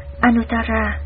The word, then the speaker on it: Anuttara: Chữ A Nậu Đa La, nguyên âm Ấn Độ đọc là Anuttara, dịch ra chữ Hán là vô thượng, nghĩa là trên hết.